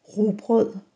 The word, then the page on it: rugbrød: Danish